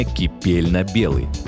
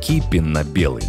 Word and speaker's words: кипенно-белый: В интернете порой можно встретить выражение «кипельно-белый» или еще «кипейно-белый». Запоминаем, это ошибка. Правильно говорить и писать только «кипенно-белый» с ударением на первый слог.